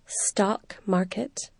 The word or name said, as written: stock market